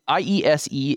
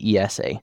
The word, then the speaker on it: IESE: It's IESE in English, and in Spanish, typically they say ESA.